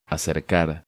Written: Acercar

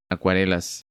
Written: Acuarelas

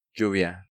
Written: Lluvia

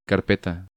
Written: Carpeta

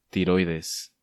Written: Tiroides

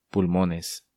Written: Pulmones

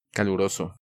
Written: Caluroso

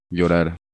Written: Llorar

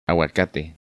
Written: Aguacate

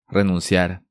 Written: Renunciar

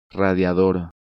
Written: Radiador

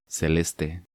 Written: Celeste

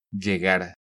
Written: Llegar

